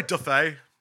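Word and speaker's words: auto-da-fé: Is it auto da fe? – I'd say da fe, yeah.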